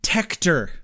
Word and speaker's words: Tector: Tector. – T-E-C-T-O-R, Tector.